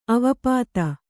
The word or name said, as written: ಅವಾಪ್ತ